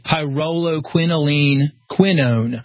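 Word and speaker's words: pyrroloquinoline quinone: That is the BioPQQ, and the way it's pronounced is pyroloquinoline quinone. And that...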